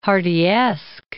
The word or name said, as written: Hardyesque